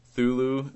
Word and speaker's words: Cthulhu: Yeah, how do you pronounce it? – I don't know how to pronounce it. – Spell it. – I think it's Cthulhu. – It's like C-T-H-U-L-H-U. Thulu. That's it sounds great to me.